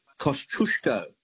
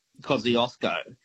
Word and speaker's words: Kosciuszko: pronounced every day of the week, and that is Kosciuszko, as in Mount Kosciuszko, the highest mountain in Australia, right? Mm-hmm. That's how the Poles would pronounce it, not Kosciuszko.